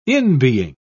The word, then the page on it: inbeing: British English